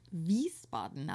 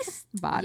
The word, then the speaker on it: Wiesbaden: The city I was born in, I say Wiesbaden, but it's pronounced Wiesbaden, I believe. – Wiesbaden.